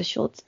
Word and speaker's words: Schultz: Howard Schultz, creo que se pronuncia Schultz, es un apellido alemán, director ejecutivo de Starbucks, se levanta a las 5 de la mañana.